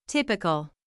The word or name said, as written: typical